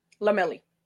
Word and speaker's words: Lomeli: And am I saying your last name correctly? – Lomeli. – I love that. Okay, great. I'm glad I got that correctly.